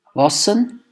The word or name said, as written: Wassen